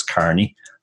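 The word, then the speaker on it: Kearney: Actually, the second name people generally get wrong as well because of the EA, which looks like E, but it's actually pronounced just like an A. So it's Carney, as in somebody who works in a Carney.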